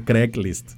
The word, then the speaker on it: Craigslist: No Craigslist, se dice Craigslist. Craig. Como si fuera Greg, pero con C.